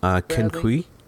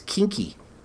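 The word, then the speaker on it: quinqui: Kinqui? – No, I think you're spelling that, it's Kinky. – Kinky? – Kinky. – Kinky. I was saying Kinke, but then I saw a corrected pronunciation of Kinkies. – Okay, well, it's Q-U-I-N-Q-U-I, and it's Spanish, so what's the right way to say it? – Kinky.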